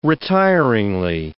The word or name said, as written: retiringly